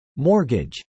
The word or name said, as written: mortgage